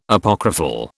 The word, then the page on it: Apocryphal: British English